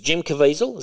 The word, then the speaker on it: Caviezel: So how do you say his name? Is it Jim Caviezel? Is that how you pronounce it? – Yeah.